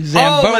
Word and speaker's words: Zamboni: Yeah, when it was probably, like, a Zumba size. – A what? – No. – Okay, all right. – I mispronounced that. You mean Zamboni? – Oh, Zamboni.